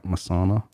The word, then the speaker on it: Masana: Off of their album Masana Temples, I think I'm pronouncing that right. Masana, that's how I pronounced it.